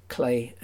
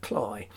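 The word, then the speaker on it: Cley: And perhaps I better say at this point that I pronounce it Clay and not Cly. That's because when I went up in the 1970s to do some research up there, it seemed to me that everybody there said Clay.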